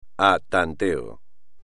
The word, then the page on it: a tanteo: espagnol